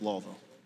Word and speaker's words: Louisville: You know, top realtor that specializes in equestrian properties in Louisville, mm-hmm. right? Or Louisville, sorry, mm-hmm. for my Kentucky folk. – Yeah, it's Louisville. – Louisville. – The eye is silent.